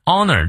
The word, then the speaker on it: honor: Of honor honor，这个 h 不发音。